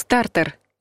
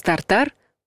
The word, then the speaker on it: тартар: Соус тартар – неправильный вариант. Соус тартар – правильный вариант.